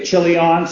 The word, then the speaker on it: Chilion: Is it Chilion or Chilion? You like to emphasize, okay, Chilion.